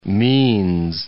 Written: means

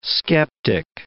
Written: skeptic

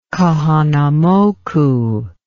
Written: Kahanamoku

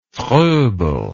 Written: Froebel

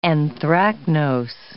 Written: anthracnose

